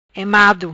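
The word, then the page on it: Emado: Patua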